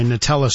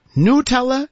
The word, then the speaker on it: Nutella: Sharon says it's pronounced Nutella. N-E-W-T-E-L-L-A. Everyone agrees on the end. Nobody agrees on the first syllable. Nutella? Nutella? Must be pronounced with the Italian oo sound for you. Nutella, N-O-O-double-O is how one listener says you need to pronounce it.